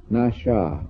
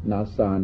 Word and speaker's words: nasha: And it uses this same word, nasha, it's pronounced in Babylonian, nasa in Hebrew.